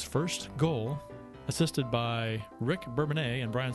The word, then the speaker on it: Bourbonnais: Bourbonnais, is that pronounced that right? Bourbonnais. – Yeah, that's correct.